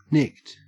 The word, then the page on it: nicked: Australian English